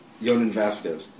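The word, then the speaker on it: Jhonen Vasquez: How do you say Jonan Vasquez? – Jonan Vasquez. That's the right way to say.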